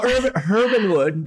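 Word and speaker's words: Herb & Wood: Well, Herbinwood, we pronounce the H, we pretend like we're in Australia or London. – Herb. – Because there is a herb, there is an H in it.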